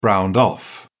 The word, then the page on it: browned off: British English